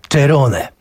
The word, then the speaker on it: Cerrone: czyli perkusisty Marka Cerrone, syna włoskich emigrantów, stąd też w obiegu jest również wymowa Cerrone.